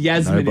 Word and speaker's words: Yasmin: Well, oh, it's Yasmin. – Nobody cares. – It's a soft J.